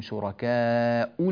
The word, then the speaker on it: شركاء: أيضا ننبه إلى رسم المصحف كذلك في كلمة شركاء، أن الهمزة رسمت فيه على واو كما ترون، وأن هذه الواو لا أثر لها في النطق، يعني هذه الواو لا تؤثر في النطق أبدا. عندما نصل نقول أنهم فيكم شركاء لقد تقطع، وعندما نقف نقول شركاء، والواو لا وجود لها في النطق أبدا، إنما هي واو في الكتابة فقط، ولها فوائد في بعض القراءات الأخرى.